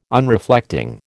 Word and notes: Unreflecting: American English

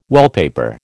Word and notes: wallpaper: American English

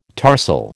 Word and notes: tarsal: American English

